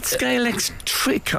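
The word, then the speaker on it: Scalextric: I was a bit worried about the pronunciation of Scalex. Well, is it Scalex trick, I think, isn't it? – Yeah. – And you made a common error. A lot of people put X at the end.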